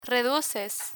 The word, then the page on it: reduces: Spanish